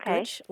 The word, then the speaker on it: scutch: I would spell it S-C-U-T-C-H. – Okay. S-C-U-T-C-H. That sounds about right. – Yeah, that's pretty close. I more often see it as S-K-U-T-C-H, pronounced scooch, like you said.